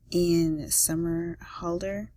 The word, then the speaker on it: Somerhalder: I just have plenty, but right now I think my celebrity crush has to be, Ian Somerhalder.